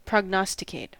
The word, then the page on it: prognosticate: American English